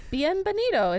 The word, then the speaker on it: bienvenido: Embanido a Miami. – Yep. Close enough. – That's what it is. It's embanido. Bienvenido is how you say it.